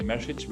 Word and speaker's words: Mesic: It's actually message, which is super hard to pronounce for American speakers.